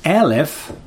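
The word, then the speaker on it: Elef (אלף): Datzelfde woord Alef, uitgesproken als Elef, maar je schrijft het exact hetzelfde, betekent het getal 1000.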